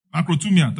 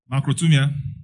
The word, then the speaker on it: makrothumia: Macrotumia, thank you. M-A-K-R-O-M-A, it's like okra. M-A-K-R-O-T-H-U-M-I-A. If my wife cook okra for you, it will remain the same again. Macrotumia, it means forbearance, it means patience.